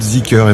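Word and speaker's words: Zeekr: Vous la prononcez comment? – Zicker. – Alors, ça va être Zicker, effectivement.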